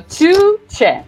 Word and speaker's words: Tugce: Two. Tugce. Imagine two Che Guevaras. So, Tugce.